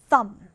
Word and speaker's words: thumb: 그리고 이때 B는 발음이 나지 않아요. 그래서 thumb, thumb입니다.